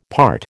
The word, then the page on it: part: American English